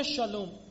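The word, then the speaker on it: شالوم (שלום): در زبان عبری خیلی وقتا، نه همیشه، سین رو شین تلفظ میکنن، سلام رو میگن شالوم، نسا رو میگن نشاییم، موسا رو میگن مشه، مسیح رو میگن ماشیح.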